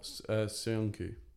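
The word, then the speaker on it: Söyüncü: I just think if he is going to play three at the back from now on, Rodgers, maybe that's Soyun Kuli. Is that the Turkish? – Oh, Soyuncu. – Yeah, the centre back.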